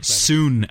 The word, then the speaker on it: Sun: But this is based on the two sons of the Sun family, so S-O-N-S of the S-U-N family, or Soon as it's pronounced in the thing, but it's the brother's son.